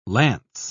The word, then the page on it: launce: British English